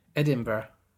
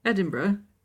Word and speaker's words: Edinburgh: Why did you pronounce it like that? – Is that not how it's pronounced? – Edinburgh? – Edinburgh. – Edinburgh.